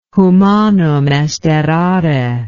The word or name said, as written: humanum est errare